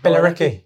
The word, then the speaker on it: Billericay: But did you go to Billericay? What is it? How do you say that? – Billericay.